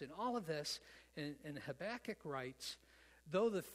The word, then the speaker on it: Habakkuk: Oh boy, somebody else pronounced his name right now. Habakkuk, yeah.